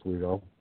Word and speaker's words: Oswego: Lake Oswego? I think that's how you say it. Do you know how you pronounce it? – I believe it's pronounced as Oswego.